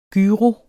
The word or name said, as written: gyro